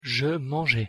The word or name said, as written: je mangeais